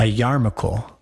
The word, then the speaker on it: Yarmulke: A Yarmulke.